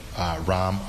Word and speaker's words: Ram: And my name is spelled Ram, R-A-M, as in Mary. First name, last name, Villivallum, V as in Victor, I-L-L-I, V as in Victor, A-L-A-M, as in Mary.